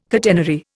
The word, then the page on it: catenary: Indian